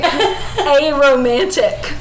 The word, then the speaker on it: aromantic: Aromatic is like when something smells good. So explain that one to me. – Maybe. No, I said that wrong. It's actually aromantic.